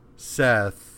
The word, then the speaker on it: Seth: Which he blasts Set. It's spelled Seth, so I'm just like, in my notes, I'm fucking up. He blasts Set, which causes Set to lose a hand, and then, like, the ship crashes, and all of the dead soldiers are dead now.